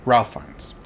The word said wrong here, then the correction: Ralph Fiennes: I thought you pronounced it Rafe. – Maybe. Oh, yeah, Rafe Fiennes. You're right.